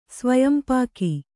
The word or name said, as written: ಸ್ವಯಂಪಾಕ